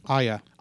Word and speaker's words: Aya: So in the last bit of news, the first images of Studio Ghibli's debut CG animated feature, A-Y-A. – Aya.